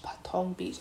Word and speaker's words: Patong Beach: Anyway, we had some breakfast and our hotel was across the road from Patong Beach. Patong Beach, I said that right.